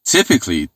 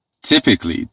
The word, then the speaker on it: typically: یه نکته هم در مورد تلفظ کلماتی که در واقع ادورب هایی که در واقع C-A-L-L-Y دارن یعنی کالی مثل همین typically. ببینین دوستان تلفظ درستش typically, mechanically, physically. بچه ها متاسفانه به اشتباه مثلا میخونن typically یا physically. حواستون باشه. Typically, physically, grammatically.